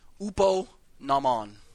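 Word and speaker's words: Upo nomon (ὑπὸ νόμον): The H is silent. Upo nomon.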